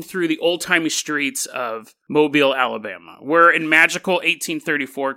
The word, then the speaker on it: Mobile: We're headed to Mobile, Alabama. I don't know why they pronounce it like that, but they do. Mobile, Alabama.